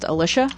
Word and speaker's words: Alicia: The only thing, my name is pronounced Alicia. – Alicia, okay. – Yep. So that was the only thing. – Okay. – Like Alicia and the Militia.